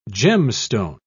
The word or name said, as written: gemstone